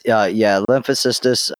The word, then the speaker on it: lymphocystis: Can you ask Dr. Fish about lymphocystis? I think I said it right. – Yes, lymphocystis.